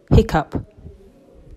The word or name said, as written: Hiccough